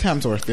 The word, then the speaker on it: Hemsworth: Helmsworth. Christopher Helmsworth, right? – Yes. – Thor. – Chris Hemsworth, yeah.